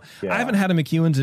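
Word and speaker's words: McEwan's: M-C-E-W-A-N, apostrophe S, McEwen's.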